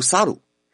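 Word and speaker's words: 猿: 原来“ 猴” 字在日语中读“ 萨鲁”，这正好和日语中“ 离开” 的发音是一样的，所以在日本猴年有灾难都离开的含义。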